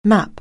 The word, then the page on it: map: anglais